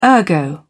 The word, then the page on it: ergo: British English